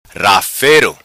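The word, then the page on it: Rafero: patua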